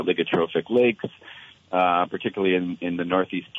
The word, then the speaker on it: oligotrophic: First of all, explain what, and I might need help with the pronunciation of this, an oligotrophic lake is. – Sure, happy to. Your pronunciation was very good. We say oligotrophic.